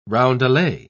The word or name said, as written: roundelay